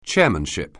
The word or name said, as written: chairmanship